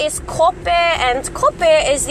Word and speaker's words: kope: And kope is spelled K O P E, and it's pronounced ko pe.